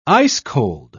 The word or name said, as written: ice-cold